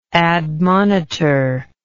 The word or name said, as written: admonitor